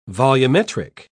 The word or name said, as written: volumetric